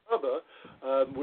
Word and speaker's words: Ntaba: The name in Ntaba, which is spelled N-T-A-B-A, is our last name in Zulu, and that means mountain.